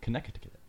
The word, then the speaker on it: Connecticut: Connecticut. – How many T's are in there? – A lot. – There's a silent C.